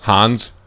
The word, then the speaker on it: Hans: And then I think it's Agent Hines? How is that pronounced? – Hans.